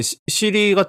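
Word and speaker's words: Siri: 예, 시리 같은 경우는 이제 그 발음을 할 적에 R이 발음이 되게 똑똑히 안 들리도록 발음을 한대요. 좀 이렇게 흘려서 얘기를 한다는 거겠죠.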